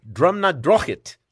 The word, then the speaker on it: Drumnadrochit: It, I believe it is pronounced drumnadrochit. Drumnadrochit.